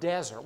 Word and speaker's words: desert: A desert has the emphasis on the first syllable, right? Desert. What if you change it and put the emphasis on the second syllable? Desert. Not dessert that you eat. I don't mean with two S's. I mean spelled the same way.